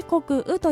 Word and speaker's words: 穀雨: 穀雨、こちらは穀物の穀に雨と書いて穀雨と読みますが、二十四節気の一つでして、雨が 100 種の穀物を生じさせる時期のことを指しております。